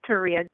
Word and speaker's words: Taria: This is Taria. Good afternoon, ladies. – Hi. Say your name again. I couldn't get that. – It sounds like Maria.